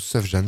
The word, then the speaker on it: Sufjan: Et oui, retour de Sufjan Stevens, qu'on prononce Sufjan ce soir. – Ouais, voilà. Non pas Sufjan.